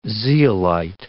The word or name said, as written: zeolite